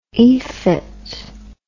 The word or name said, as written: efit